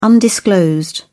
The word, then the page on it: undisclosed: British English